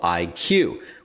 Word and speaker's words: EyeQue: We're now going to learn about a company and product called IQ, which is spelled E-Y-E-Q-U-E, with Phoebe Yu.